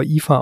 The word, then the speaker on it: Aoife: Die wird Aoife geschrieben, der Vorname, aber Aoife ausgesprochen.